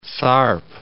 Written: Tharp

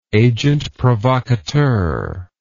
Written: agentprovocateur

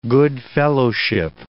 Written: good-fellowship

